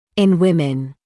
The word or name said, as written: in women